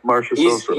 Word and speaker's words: Marchessault: Like Jonathan Marchessault, I don't know, I might have butchered that name, Nemestikov and Yanni Gord. Obviously, Marchessault, is that how you say it? Marchessault? – Yeah, Marchessault.